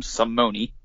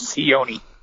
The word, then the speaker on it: Sione: He calls him Simone. He's standing right there, his name's Sione.